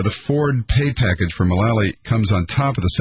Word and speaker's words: Mulally: Mulally.